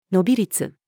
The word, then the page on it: 伸び率: female